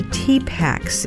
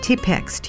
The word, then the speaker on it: Tipex: They're called the T-Packs. It's the English pronunciation of their real name, T-Pex, T-I-P-E-X, which means whiteout.